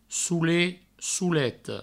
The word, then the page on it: solet, soleta: soulet; souleto